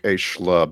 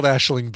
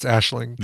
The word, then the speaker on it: Aisling: I think it is called Aisling B. Like, I think from that Taskmaster season, I think we finally learned how to pronounce it. – It is. – Claire can confirm, but I think it's Aisling. – The only part I could get was B, but Aisling? A-I-S-L-I-N-G.